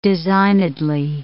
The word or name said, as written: designedly